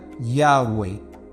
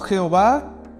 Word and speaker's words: YHWH: que es YHWH, que según los estudiosos más, más avanzados, dicen que eso se pronuncia Yahweh, ¿verdad? No Jehová,